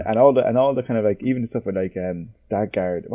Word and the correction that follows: Skarsgård: what's it, is that how you pronounce his name? – Skarsgård. – Yes, Skarsgård.